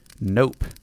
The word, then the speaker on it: Gnope: I was playing a rock gnome named Nope, or Gnope. I'm guessing the G is silent, like in gnome.